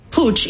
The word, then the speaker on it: Puig: And by the way, you do pronounce it Puig, even though it's spelled P-U-I-G.